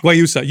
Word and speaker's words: guayusa: And you wanted, and I hope I'm saying it right, guayusa? Did I say that right? – Guayusa. – You wanted guayusa.